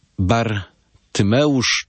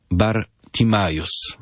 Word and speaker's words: Bartymeusz: Bartymeusz czy Bartimajus.